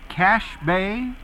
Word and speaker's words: Cache Bay: Where, did Dick say it right? Cache Bay? – Yes, that's it.